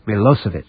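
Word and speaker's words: Milošević: It's spelled Milosevic, but it's pronounced Milosevic.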